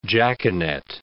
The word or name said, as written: jaconet